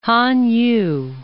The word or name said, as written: Han Yu